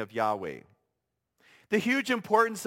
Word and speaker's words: Yahweh: Now, in an attempt to pronounce this name, in English, we often write it as Y-A-H-W-E-H, or Yahweh in English.